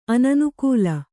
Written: ಅನನುಕೂಲ

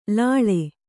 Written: ಲಾಳಿ

